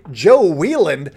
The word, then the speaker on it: Wieland: Anyways, let's talk about Joe Wieland, and that is how you say it, even though it looks like Wieland, like Scott Wieland, the Melody Man, which is a deep cut.